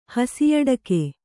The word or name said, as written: ಹಸಿಯಡಕೆ